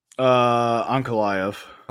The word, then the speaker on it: Ankalaev: The last one got, I think it was ruled a no contest because Ankalaev, is that how you pronounce it, Ankalaev? – Ankalaev.